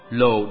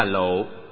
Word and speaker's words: lộ: Cam là ngọt, lồ đọc đúng là lộ, nghĩa là xương.